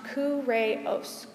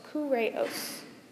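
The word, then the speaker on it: kureos (κύριος): And Lord in the New Testament is a Greek word, and it's pronounced kureos, kureos, Lord.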